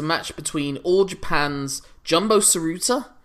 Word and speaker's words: Tsuruta: And it's, in case you're curious, it's Tsuruta, Tenryu,